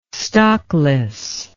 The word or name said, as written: stockless